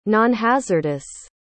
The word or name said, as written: nonhazardous